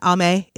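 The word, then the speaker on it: Ami: And Ame is how it's pronounced in the Japanese.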